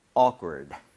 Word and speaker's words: awkward: 아, 여기서 희한하게도 K가 좀 된 소리가 나기는 해요. 그래서 awkward보다는 awkward처럼 약간 된 소리 느낌으로 두 번 발음해 보도록 하겠습니다. Awkward, awkward, awkward.